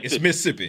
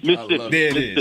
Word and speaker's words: Mississippi: And when you say Mississippi, you got to cut out one of the syllables, so it's Mississippi. – Oh, you got to right. Mississippi. It's Mississippi.